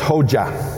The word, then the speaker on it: Hoxha: And then the president, that's this guy, Enver H O X H A, which pronounced Hoja.